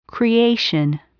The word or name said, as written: creation